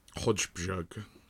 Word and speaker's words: Højbjerg: I know that's a case in like German with Müller. And it's J's either side of the B. That's how I remember it. – Højbjerg. Højbjerg. – But I was still ending it on like a hard G.